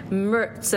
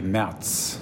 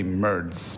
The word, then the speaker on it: Merz: And last night he said, you have to say it right. It's Merz, and the R is soft. So let me hear a really good pronunciation of... – So in German, it would be Merz.